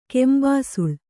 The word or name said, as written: ಕೆಂಬಿಸಿಲ್